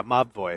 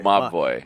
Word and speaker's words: Mobvoi: Mobvoi was out in the sands, but they finally I understand how you pronounce that. – Yeah, Mobvoi. – Mobvoi.